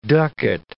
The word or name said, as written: ducat